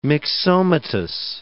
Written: myxomatous

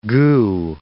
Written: goo

